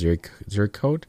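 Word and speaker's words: Ziricote: Zuricote. – Zuricote. Yeah. – I think it may also be pronounced Zuricote. I think it's Z-I-R-I-C-O-T-E.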